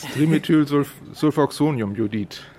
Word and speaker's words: Trimethylsulfoxoniumjodid: Trimethylsulfoxinumioxide. Habe ich es richtig ausgesprochen? Das ist ja ein umständliches Wort. – Ja, nicht ganz. Trimethylsulfoxoniumjodid.